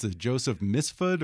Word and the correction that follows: Mifsud: or Misfood? What is it? – Joseph Mifsud. Mifsud. How did you first come into contact with this character? – Oh my goodness.